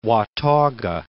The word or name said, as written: Watauga